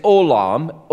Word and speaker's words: olam: Now, that, that phrase from everlasting, the Hebrew term there is olam, O L A M, short A, long O.